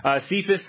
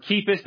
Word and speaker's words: Cephas: Cephas or Kephas, depending on how you pronounce it, is Aramaic for rock or little stone.